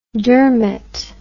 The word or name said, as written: dermat